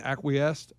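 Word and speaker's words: acquiesce: They acquiesced, whatever the heck that means, acquiesced. – Yeah, you're right. You said it right.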